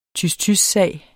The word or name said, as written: tys-tys-sag